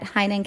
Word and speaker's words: Heynen: You can find me on Instagram at Heinen Counseling and Coaching. Last name is spelled H-E-Y-N-E-N.